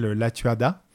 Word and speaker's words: Lattuada: Et celui qu'on a entendu, alors ce n'est pas toi, euh, c'est le comédien qui s'appelle Axel Latuada. – C'est ça, ça qu'on prononce.